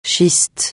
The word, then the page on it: schiste: francais